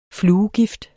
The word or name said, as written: fluegift